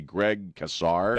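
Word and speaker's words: Greg Casar: Greg Kassar.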